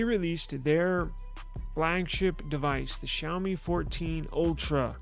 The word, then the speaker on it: Xiaomi: It's pronounced Xiaomi. It's spelled X-I-A-O-M-I.